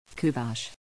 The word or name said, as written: Kuvasz